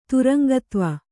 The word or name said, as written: ತುರಂಗತ್ವ